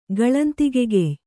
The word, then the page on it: ಗಳಂತಿಗೆಗೆಯ್: kannada